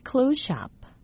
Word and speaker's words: clothes: close의 THES 발음과 뒤에 따라오는 shop의 SH 발음이 겹쳐서 clothes shop.